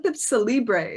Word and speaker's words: Cellibre: When I looked up Cellibre, is that how you pronounce it? – It is.